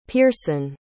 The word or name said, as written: pearson